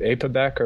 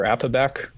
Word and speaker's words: APOBEC: So so the APOBEC enzymes, one can pronounce it APOBEC or APOBEC kind of like tomato tomato.